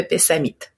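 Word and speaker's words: Pessamit: Oui, en fait on dit Innu, le Innu, d'accord. – On prononce ou donc Innu de Pessamite.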